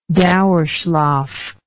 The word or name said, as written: dauerschlaf